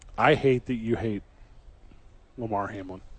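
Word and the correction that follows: Damar Hamlin: DeMar Hamlin. – He does not hate DeMar Hamlin. – I like him enough to get his name right. – I fixed it like right away.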